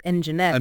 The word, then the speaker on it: Nginx: And while Apache is the traditional A in LAMP, it's worth knowing that other web servers like Nginx, pronounced Nginx.